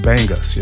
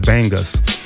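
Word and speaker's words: Bangas: That was coming from, what is it, Tank and the Bangles. The Bangus, yeah. Pronounce it correctly, Chucky. Pronounce it correctly. Tank and the Bangus.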